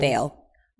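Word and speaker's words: Viall: And then now to piggyback off that, when Kristen went on Nick Vial, however you pronounce his name. – Vale.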